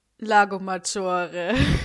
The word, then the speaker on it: Lago Maggiore: Lago Maggiore. – Ja, genau, so spricht man das aus.